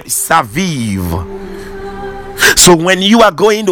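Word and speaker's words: saviv (סביב): Now that word "round about" in the Hebrew is the word saviv in the Hebrew is the word saviv, but it is spelled as S A B I B, but the pronunciation is saviv.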